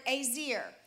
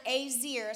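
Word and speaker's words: ezer: It's the Greek or the Hebrew word azir. It's E-Z-E-R. You can go look it up for yourself. The word azir is how it's pronounced.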